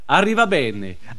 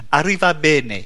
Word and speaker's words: Arrivabene: Alors, répète après moi. Maurizio. Maurizio. Arriva bene. Arriva bene.